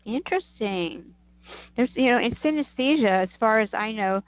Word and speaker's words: synesthesia: synesthesia? – Yes, thank you.